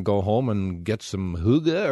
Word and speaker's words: hygge: I should tell people that it's spelled H-Y-G-G-E, so that's where it's tough to learn the pronunciation. And if you don't do the very often, it's tough to do that as well. So hygge.